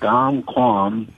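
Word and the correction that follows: Dong Phuong: it's a Vietnamese bakery widely known for king cakes. – Dong Phong. – Dong Phong, that's it. There you go.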